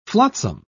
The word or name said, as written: flotsam